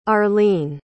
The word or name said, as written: arleen